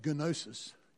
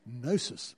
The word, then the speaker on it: gnosis: The Greek word is gnosis. It's with a G, but you don't say the G. It's not gnosis. It's gnosis. They even tell you how to pronounce the word.